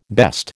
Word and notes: best: American English

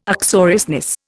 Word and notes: uxoriousness: Indian

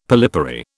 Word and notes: polypary: British English

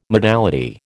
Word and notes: modality: American English

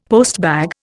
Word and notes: postbag: Indian

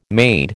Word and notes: made: American English

